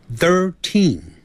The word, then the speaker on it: thirteen: T-H-I-R-T-E-E-N，所以 T-H-I-R 有一个 T-H，舌头就要上齿跟下齿轻轻咬住舌头吐出来 third，然后 third 赶紧往里头说啊 thirteen，这个有轻重音之别，不要念成 thirteen，那个 teen 要重读，也就是读出来的时候声音比较重一点 thirteen，